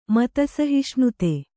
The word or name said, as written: ಮತಸಹಿಷ್ಣುತೆ